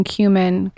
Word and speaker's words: cumin: I just took my turmeric and cumin. Is that how you say it? Cumin? Yeah. Black pepper pill.